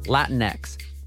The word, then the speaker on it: Latinx: L-A-T-I-N-X, Latinx, pronounced Latinx, refers to people of Latin American heritage.